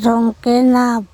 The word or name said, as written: THongkenap